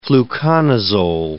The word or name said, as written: fluconazole